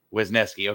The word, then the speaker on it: Wesneski: Wesneski, okay.